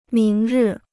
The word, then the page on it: 明日: míng rì